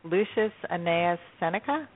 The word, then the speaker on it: Lucius Annaeus Seneca: And that's by, I'm going to probably pronounce this incorrectly, Lucius Annaeus Seneca. Is that how you pronounce it? I believe that's correct.